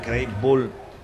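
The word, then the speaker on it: Krehbiel: Ah, dice que se pronuncia Craig Bull.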